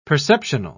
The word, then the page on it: perceptional: British English